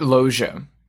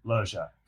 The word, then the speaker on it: Loggia: Robert Loggia. – Loggia.